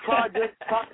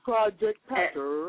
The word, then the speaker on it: Project Pat: And we also have Project Pat coming through. – You ain't saying it right, girl. It's Project Pat. You got to get it right, baby. It's Project Pat.